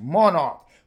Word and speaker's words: MNRK: Now, MNRK is pronounced Monarch.